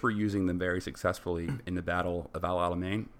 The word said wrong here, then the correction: El Alamein: Am I saying that correctly? – El Alamein.